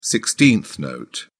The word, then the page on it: sixteenth note: British English